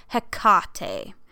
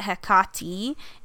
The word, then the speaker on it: Hecate: generally with Greek words of three syllables, the accent is in the middle, hence the correct pronunciation of her name is Hecate. And they have, like, the phonetic, you know, H-E-H, tack K-A-H, tack T-E-H. And they say that Hecate is also an acceptable pronunciation.